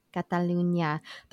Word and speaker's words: Catalunya: I think it's Catalonia. Hope that's how you say it. – It's the region. Catalunya.